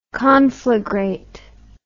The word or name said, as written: conflagrate